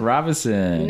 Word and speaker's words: Robison: Robison. – No middle N. It's not Robinson. It's Robison. – And it's not pronounced Robison. – It's not pronounced Robison. – Yeah. – Kind of like Roy Orbison.